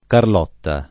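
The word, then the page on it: Carlotta: italiano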